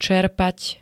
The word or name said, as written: cerpat